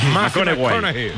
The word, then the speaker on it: McConaughey: Sí, que hay que ir corrigiendo la pronunciación. – McConaughey. Matthew McConaughey. Bueno, pues el Matías...